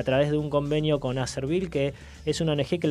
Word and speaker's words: Acerbil: Repetí el nombre, Acervil. Acervil, se escribe A-C-E-R-B I-L.